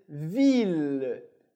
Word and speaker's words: ville: Muito importante que o E no final da palavra, ele não é pronunciado, ele desaparece. A letra que vem antes, no caso o L, a gente pronuncia, por isso ville, mas eu não pronuncio o E.